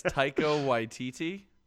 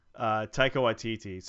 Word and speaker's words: Taika Waititi: It's Taika Waititi. – Taika Waititi.